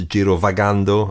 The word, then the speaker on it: Girovagando: I mean, looking forward to Girovagando. I'm going to keep saying it now that I've been told that my pronunciation's acceptable.